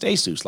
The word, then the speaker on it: ASUS: It's pronounced ASUS, like Dr. Seuss.